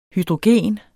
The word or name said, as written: hydrogen